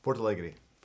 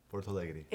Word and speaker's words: Porto Alegre: Porto Alegre. – Porto Alegre. – Porto Alegre.